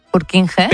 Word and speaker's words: Purkinje: Es que se escribe, en español sería Purkinje. – Purkinje. – Pero Purkinje es en checo, que es por porque es el apellido del descubridor,